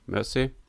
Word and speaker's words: Mersey: Und ähm, in Anlehnung an den Fluss Mer Mersey oder Mersey, wie würdest du so aussprechen? Genau so. Mersey.